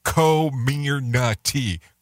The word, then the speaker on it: Comirnaty: It's pronounced Comirnaty, according to the FDA.